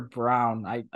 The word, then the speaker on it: Braun: Wow, Parker Brown. I know his name's Brown, not Braun, even though it's spelled like that.